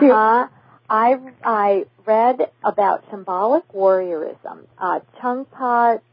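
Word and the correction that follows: Chögyam Trungpa: And in the book, Choegum Trungpa, there we go.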